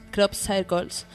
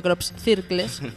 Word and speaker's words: crop circles: crop circles, como bien él me ha enseñado a pronunciar, menos mal que no he dicho crop circles, que es como se escribe, ¿vale?